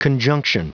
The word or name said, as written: conjunction